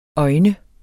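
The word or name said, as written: øjne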